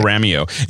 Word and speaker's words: Panoramio: Like Daddy-O with Panoramio. – Panoramio.